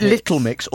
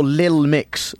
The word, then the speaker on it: Little Mix: Is it Little Mix or Lil Mix? – It's spelled correctly. – Right. Not like a rapper.